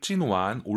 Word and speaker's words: 今晚: 여러분들 찐완 올리신 거 맞죠? 삼성 삼성이니까 완 올라가야죠.